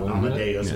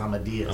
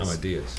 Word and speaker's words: Amadeus: Well, let's, if you guys are up to it, why don't you guys play, if I'm saying it right, Amadeus? – Mm-hmm. – It's not Amadeus, it's Amadeus. – Amadeus.